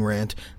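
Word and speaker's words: Wrant: at Wrestling Rant. That is right, at Wrestling Rant. Rant is spelled with a W. Put a silent W in front of that bitch.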